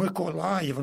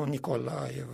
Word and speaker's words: Mykolaiv: Mykolaiv, nu Nicolaev și tot așa.